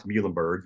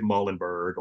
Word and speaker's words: Muhlenberg: And mainly one thing was to help people pronounce Muhlenberg. You nailed that one too, by the way. – Thank you. – Most people pronounce it Muhlenberg or some monstrosity of a word, the way they just say it.